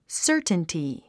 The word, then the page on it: certainty: sə́ː r tnti; サァタンティ